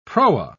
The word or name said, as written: proa